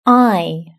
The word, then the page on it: eye: British English